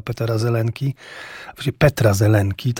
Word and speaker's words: Petra Zelenki: Chodzi o niezwykły zupełnie pokaz, wyjątkowy pokaz filmu Rok Diabła Petra Zelenki, tak należy prawidłowo wymawiać imię tego reżysera.